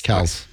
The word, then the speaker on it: Kelce: The name is Kelce. – No, I watch ESPN and all that stuff, and guess what? They still say Kelce.